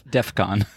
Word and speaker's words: DEFCON: It's not death. – Oh. – It's DEFCON. – Death? Like they can't hear? – Yeah, but it's not that either. Oh. It just sounds like it.